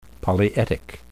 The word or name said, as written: polyetic